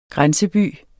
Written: grænseby

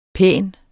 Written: pæn

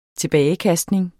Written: tilbagekastning

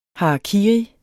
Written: harakiri

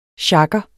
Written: sjagger

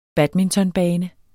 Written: badmintonbane

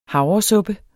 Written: havresuppe

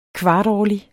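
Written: KVARTÅRLIG

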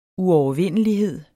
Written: uovervindelighed

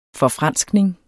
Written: forfranskning

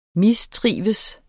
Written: mistrives

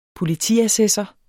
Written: politiassessor

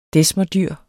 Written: desmerdyr

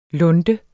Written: lunte

